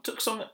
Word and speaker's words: Tucson: Tucson? Tucson, Arizona? – That's right. That's how it's spelt. So it must be Tucson, right? – Tucson, yeah.